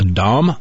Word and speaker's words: Daum: And this is written by, I do want to point out who this is written by, Kevin, I guess his name is pronounced Daum, D-A-U-M, Kevin Daum.